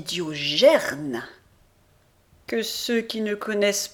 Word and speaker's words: Diogène: Je dis à Rogier qui est là qu'il ne doit pas dire Diogène mais Diogerne. Pas gêne, gerne.